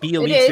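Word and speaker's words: Bjelica: The backup five spot The best, your best option is Ariza and Iggy, because Bielitsa, and yes, I can say Bielitsa, motherfuckers. I hope I'm saying that right, because I talk a lot of shit. – I think that is right. I think Bielitsa is correct.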